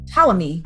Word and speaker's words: Ptolemy: Ptolemy. P is silent.